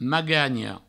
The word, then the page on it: maganha: magagno